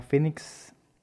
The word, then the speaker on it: Phoenix: Sí, yo les voy a recomendar un disco de una banda que se llama Phoenix. Estas sí son gabachos. Phoenix como se escucha pero en inglés. O sea, se escribe P-H-O-E-N-I-X.